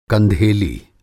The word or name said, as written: कंधेली